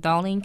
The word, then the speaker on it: Darlene: I'm on Facebook, Darlene Kane, and that's D-A-R-L-E-N-E, last name is spelled Kane, C-A-I-N.